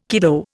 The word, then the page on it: kiddo: Indian